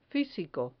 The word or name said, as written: fisico